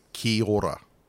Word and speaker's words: kia ora: So it's K-I-A-O-R-A, so kia ora. But the way Māori pronunciation is, it becomes basically ki ora. So, just ki ora.